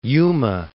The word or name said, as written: Yuma